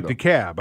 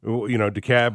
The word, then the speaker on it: DeKalb: DeKalb. I mean, DeKalb versus, DeKalb, Illinois, or DeKalb corn. You pronounce the L down here. It's down here. It's silent. – There's DeKalb. DeKalb County. – DeKalb.